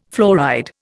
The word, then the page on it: fluoride: Indian